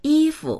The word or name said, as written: yi1fu